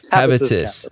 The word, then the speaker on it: Habitus: habitus? – Habitus.